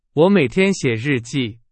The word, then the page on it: 日记: Chinese